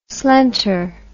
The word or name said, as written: slenter